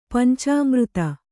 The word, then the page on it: ಪಂಚಾಮೃತ: kannada; pancāmřta